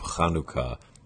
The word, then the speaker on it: Chanukah: And the ch sound at the beginning of Chanukah is the same ch sound as you have at the end of Johann Sebastian Bach.